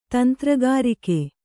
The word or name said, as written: ತಂತ್ರಗಾರಿಕೆ